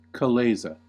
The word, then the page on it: chalaza: American English